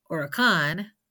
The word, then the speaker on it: huracán: Getting back to our cognate duo of hurricane and huracán, they both start with the letter H, but in Spanish that letter is silent, so begin the pronunciation with that U instead.